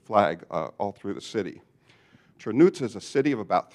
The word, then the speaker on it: Chernivtsi: Our American tongues would pronounce it Chernivtsi, if you look at it on a map. But they pronounce it Chernuts.